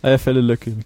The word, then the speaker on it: Eyjafjallajökull: Y-J-A. Eierfälle-Löckel. – Eierfälle-Löckel, genau.